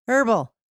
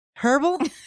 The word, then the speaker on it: herbal: It's herbal, yeah. H is silent. Like an herb.